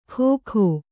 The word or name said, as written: ಖೋಖೋ